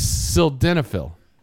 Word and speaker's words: sildenafil: It's sildenafil. That's how you say it. – Okay. Sildenafil. – S-I-L-D-E-N-A-F-I-L.